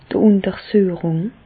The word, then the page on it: d' Ùntersüechung: Bas-Rhin; Reichshoffen